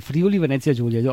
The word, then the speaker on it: Friuli Venezia Giulia: questi amici che ci vengono a trovare dal Friuli Venezia Giulia.